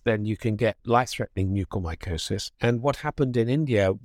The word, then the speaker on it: mucormycosis: It's been causing this infection called black fungus, or I think it's pronounced mucormycosis, which was a problem in India. – So mucormycosis has been known for a long, long time.